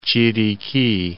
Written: Chiriqui

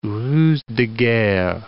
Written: ruse de guerre

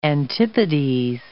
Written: antipodes

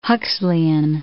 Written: Huxleyan

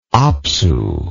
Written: apsu

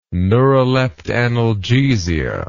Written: neuroleptanalgesia

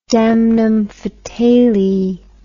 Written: damnum fatale

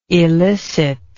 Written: elicitable

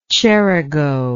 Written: cerigo

